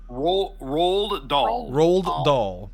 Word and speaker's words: Roald Dahl: Rolled Dahl.